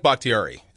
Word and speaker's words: Bakhtiari: I like Bakhtiari.